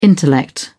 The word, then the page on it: intellect: British English